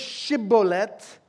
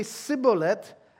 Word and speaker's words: Shibboleth: The word is Shibboleth. Ephraim doesn't have the sh sound, so they say Sibboleth. And guess what the price is for pronouncing it wrong, which reveals your tribe?